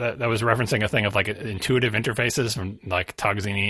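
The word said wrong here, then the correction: Tognazzini: interfaces or whatever, like Tognazini, I always get his name wrong.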